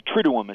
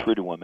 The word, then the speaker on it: triduum: I think both you and Paul are pronouncing it perfectly, as far as I'm aware. Now, you know, a single word can have many different pronunciations, but triduum is how I've heard it, so okay.